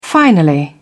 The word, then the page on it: finally: British English